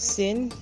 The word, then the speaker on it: Syn: My name is Syn, and Syn is spelled S Y N.